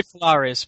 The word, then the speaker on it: Polaris: Okay, how do you say it? Polaris or Polaris? Because I say Polaris. – I say Polaris, but I'm happy either way, to be honest.